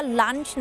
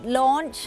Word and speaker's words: launch: มักจะอ่านว่า lunch นะคะแต่ว่าไม่ใช่ lunch นั้นมันก็คือมื้อกลางวันใช่ไหมคะอาหารกลางวันจริงๆแล้วต้องออกเสียงว่า launch นะคะนั่นก็จะเป็นสำเนียงอังกฤษ launch นะคะซึ่งหมายถึงการปล่อยเครื่องบินหรือว่าจรวดหรือว่าจะเป็นดาวเทียมก็ได้ค่ะอย่างเช่นเรามาดูตัวอย่างเป็นภาษาอังกฤษประโยคเลย The students launched a plane after they learned about the aviation theory.